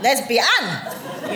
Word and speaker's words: lesbian: Because it's the word lesbian with three syllables with an emphasis on the an. Lesbian!